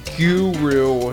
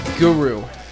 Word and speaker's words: guru: I heard the Hindi, I think it's a Hindi, it's Hindi, the word guru, pronounced guru in an American accent, but from talking with my coworkers from southern India, the proper pronunciation is guru, so I'll be using the flat U from now on.